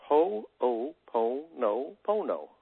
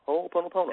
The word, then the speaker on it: Ho'oponopono: You basically just pronounce every syllable. So when you look at the word and you go Ho'oponopono. Ho'oponopono.